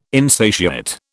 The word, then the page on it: insatiate: British English